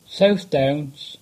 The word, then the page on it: South Downs: English